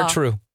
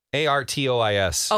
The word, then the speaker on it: Artois: I call it Stella Artrue. – Is it? Okay. – Because that's what it should be. – I think. – The S is silent.